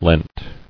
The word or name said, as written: Leant